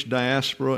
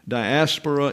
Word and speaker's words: diaspora: Diaspora Jews, notice the accent is on the alpha at the end. That is the Greek translation and pronunciation. Diaspora is English, diaspora is Hebrew.